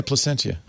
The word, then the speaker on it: Placentia: It's in Placentia. Is that how you pronounce it? – It's a California brewery. – It is. – Oh. – Yeah. – Yeah, Placentia. LA area.